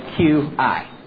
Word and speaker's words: qi: That's how you spell qi. Believe it or not, sometimes it's spelled Q-I. Okay? I don't know why. But qi, like you've heard maybe Tai Chi. Okay, that's an example of that.